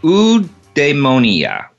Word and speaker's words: eudaimonia: It's called eudaimonia. Eudaimonia. It's spelled E-U-D-A-I-M-O-N-I-A.